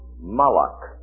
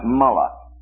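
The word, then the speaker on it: malach (מלאך): Now, in the Hebrew, the word for angel is malach. All right, malach. M-A-L. Two dots means the end of a syllable. This is a silent letter. This is A. And this is a kind of a KH sound.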